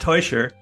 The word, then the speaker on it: Tuscher: It's pronounced Tuscher, which is tough for native English speakers, but thank you.